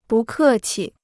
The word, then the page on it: 不客气: bù kè qi